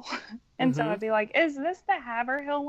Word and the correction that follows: Haverhill: line? And they're like, it's Haverhill, lady, get on.